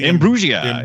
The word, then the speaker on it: Imbruglia: Natalie Imbruglia.